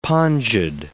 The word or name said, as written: pongid